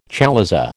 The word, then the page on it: chalaza: American English